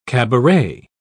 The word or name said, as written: cabaret